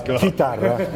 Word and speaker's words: chitarra: It's chitarra.